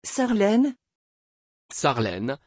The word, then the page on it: charlene: French